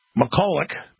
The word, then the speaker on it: McCulloch: Prosecutor Robert McCulloch, that's how you say it, played a role of Pontius Pilate.